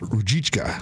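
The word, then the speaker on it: Ružička: Adam Rozitka? Rozitka, I believe his name. The guy from the Coyotes. – I believe it's pronounced Rujitka.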